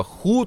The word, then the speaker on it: Who: Вони пишуться англійською The Who. Але вимовляти треба ху, тому що, як і в російській, в монгольській немає звуку г.